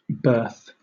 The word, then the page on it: berth: British English